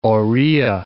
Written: Oriya

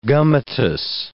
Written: gummatous

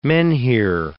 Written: menhir

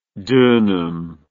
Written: dunam

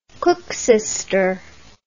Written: koeksister

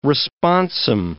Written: responsum

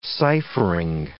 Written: ciphering

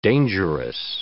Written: dangerous

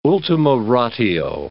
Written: ultima ratio

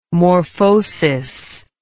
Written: morphosis

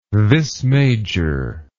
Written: vis major

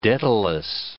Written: Daedalus

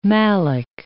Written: malic

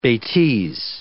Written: betises

